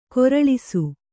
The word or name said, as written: ಖರೆಲೇಸು